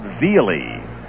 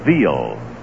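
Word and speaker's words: Veale: And there was a big left-hander with the Pittsburgh Pirates who said his name was Bob Veely. Strike two pitch to Tony outside. And then after he had been around the league for a couple of years, he pronounced it Veal.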